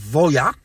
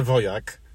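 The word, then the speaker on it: Voyah: To się nazywa Voya. Przez H na końcu. – A, nie, że Wojak. – Nie, Wojach. H na końcu, więc się nie wymawia.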